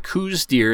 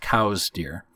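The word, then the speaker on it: Coues: But what I found out was in 1893, a historian named Elliot Cowes, it looks like Coos, and the Coos deer is actually named after him, but it shouldn't be pronounced Coos deer. It should be pronounced Cowes deer.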